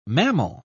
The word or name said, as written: mammal